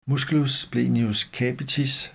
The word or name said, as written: m. splenius capitis